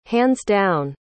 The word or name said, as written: hands-down